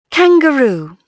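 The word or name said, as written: kangaroo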